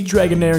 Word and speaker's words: Dragonair: As most of you already know, the proper pronunciation is Dratini, Dragonair, and Dragonite.